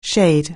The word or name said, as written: shade